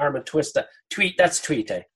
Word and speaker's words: tuite: Is it tuite or tuti? – Okay. So either or is actually fine, because it's just local. How I know is tuite, which is the digging into the cavities of the body.